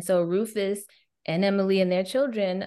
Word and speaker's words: Rufus: Rufus. – Yeah, and then you got to like that. The extra emphasis on the us. Rufus.